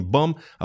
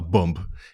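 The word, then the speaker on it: bomb: Кстати, русского человека, который даже без особого акцента говорит по-английски, можно легко отличить от нейтива, потому что он, например, говорит не бам, а бомб. И поскольку по-английски последнее «б» в слове «бомба» не произносится, то русский шпион сразу себя выдает.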